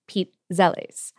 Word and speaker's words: pizzelle: The Italian side of my family hails from northeastern Ohio as well, and we pronounce it Pete Zelles.